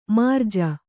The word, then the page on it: ಮೃಜೆ: mřje